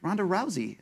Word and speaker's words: Rousey: Luke, call me crazy, but the way you pronounce the S in Ronda Rousey's last name is fucking weird. It's pronounced as if it was a S, but it really should be pronounced as if it was a Z, bro. Don't be that guy.